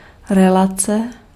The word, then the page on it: relation: [rɪ.ˈleɪ.ʃən]